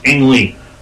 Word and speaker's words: Ang Lee: Ang Lee.